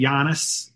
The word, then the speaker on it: Giannis: I used to write out his name phonetically, and it would always be said differently. I learned after a while, just say Giannis, you know.